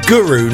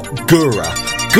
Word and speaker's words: Guru: because that was Guru, not Gura.